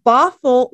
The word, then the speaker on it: Bothell: They're residents of Bothell, Washington.